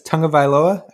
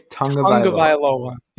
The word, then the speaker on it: Tagovailoa: So we're starting at QB 13, and that is the one and only Tua Tagovailoa. How do you say his last name, guys? Tagovailoa? I think it's Tagovailoa. – Yeah. – Tagovailoa. Mm-hmm. Like, yeah, tongue in your mouth, vi, like bye, and loa.